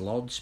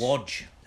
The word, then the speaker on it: Łódź: To me, it's Lodz, but you're going to tell me it's Łódź.